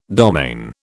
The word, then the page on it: domaine: British English